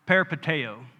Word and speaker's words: peripateo: In the Hebrew, the phrase that you walk or walk is pronounced peripateo, okay?